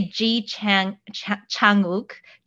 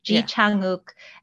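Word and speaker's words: Ji Chang-wook: is there's a lot of aspirated consonant sounds. So breathing through a lot of the pronunciation actually increases like how well good your pronunciation is. So you can say Ji Chang Uk, Ji Chang Uk, doesn't sound as like as Korean as chi chi, because you like breathe through that J chi, sound.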